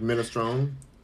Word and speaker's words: minestrone: Holly Jackson said, it's pronounced Mina. Mina. Mina. Like minestrone. Minestrone. Minestrone. Mm. Clockwise is laughing at me. Holly says it's pronounced Minestrone. Minestrone. They now want ice in this. I really want ice now. I want some more of this drink first. You know, liquor, baby. Liquor. Ooh, sizzle her. So good. Oh my god, you guys are really trying to teach me how to say this, the soup name. Rita says it's minestrone.